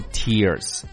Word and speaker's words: tiers: 但是要注意啊，我们还有一个单词跟它的发音是一样的，也叫 tears，但是拼写我给你拼写一下，叫做 T-I-E-R S，这个 tears 是什么呢？